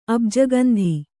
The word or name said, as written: ಅಬ್ಜಗಂಧಿ